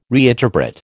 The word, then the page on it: reinterpret: American English